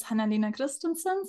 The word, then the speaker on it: Hanna Lena Christensen: My name is Hanna Lena Christensen. So thank you for saying my name the German way.